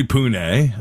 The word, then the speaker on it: Pune: It is probably Pune, I would say.